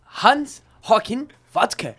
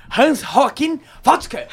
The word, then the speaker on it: Hans Joachim Watzke: Não, você pronunciou muito bem. – É. Pronuncie de novo pra nós. – Vamos lá. Hans? Não sei. Hans Joachim Watzke.